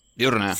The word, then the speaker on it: diurna: Me parece más incapacitante la depresión eh, duirna. Duirna, ¿cómo se dice? – Diurna.